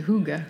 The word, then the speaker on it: hygge: And the coziness, the hygge. I'm not, am I saying that correctly? Please, you are saying it very good.